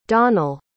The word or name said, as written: donall